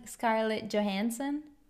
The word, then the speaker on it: Scarlett Johansson: Che in italiano noi abbiamo tutti i problemi a pronunciare il suo nome perché diciamo Scarlett Johnson, Scarlett Johansson, Scar- Scarlett Johansson? È difficile per gli italiani.